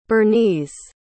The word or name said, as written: bernese